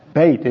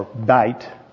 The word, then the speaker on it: Beth: And so we looked at the first letter of the Hebrew alphabet last week, which is Aleph, and this week it's Bait is the way you pronounce it, or bite. It's spelled B-E-T-H if you have the New American Standard Bible or any others that put it B-E-T-H, but you really don't pronounce the T-H. It's a hard T. It would be bait.